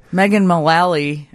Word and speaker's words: Megan Mullally: Megan Mullally. I don't know how to say her name. Is that it? – Megan, yeah. You've got it.